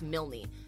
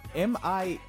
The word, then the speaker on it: Milne: I think it's pronounced Milne. – I don't know that person's name. – The last name is Milne. – Minle? – Milne.